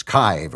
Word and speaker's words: Kyiv: Well, for some reason, we're not supposed to call it Kiev anymore. It's Kyiv or Kyiv, Kyiv, but I'm going to continue to call it Kiev because I don't really know how to pronounce the other one.